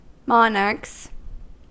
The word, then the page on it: monarchs: American English